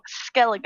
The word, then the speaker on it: Skellige: Skellige. That's how it's pronounced. You would know that if you went there because they say it all the fucking time.